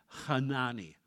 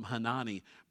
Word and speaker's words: Hanani: And his name is pronounced Hanani. It's got that guttural thing up the front. We'll skip that and just call him Hanani.